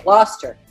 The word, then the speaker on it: Gloucester: As the founder of the Glen Highland Sanctuary, it's now located in, is it, how do you pronounce it in Virginia? Gloucestershire? Say it. I'm on radio. – Okay. Gloucester. – Gloucester. – It's Gloucester Fisherman. – All right. Gloucester. Gloucester, Virginia. Her days are very long, but she's not complaining.